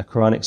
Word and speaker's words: Quranic: I've never heard of a Muslim seminary or so-called Quranic school. That's how you pronounce it, a Quranic school.